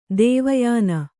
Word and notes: ದೇವಯೋನಿ: dēva yōni

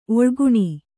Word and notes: ಒಳಗಾಣ್: oḷagāṇ

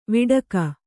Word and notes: ವಟಕ: Kannada

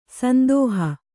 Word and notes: ಸಂದೋಹ: sandōha

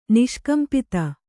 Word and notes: ನಿಷ್ಕಂಪಿತ: niṣkampita

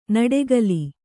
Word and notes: ನುಡಿಗಲ್: Kannada

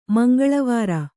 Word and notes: ಮಂಗಳವರೆ: Kannada

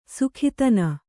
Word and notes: ಸುಖಿತನ: Kannada

